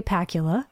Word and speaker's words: Pakula: First, though, Sophie's Choice is from 1982, directed and written by Alan J. Pakula. – Rhymes with Dracula for Halloween.